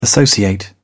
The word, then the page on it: associate: British English